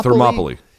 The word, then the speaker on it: Thermopylae: And it's inspired by the Battle of Thermopylae, which took place in 480 BC for all you historians, which the Thermopylae. – The film The 300 Spartans is based on, Luke. Thermopylae. – Yeah, Thermopylae.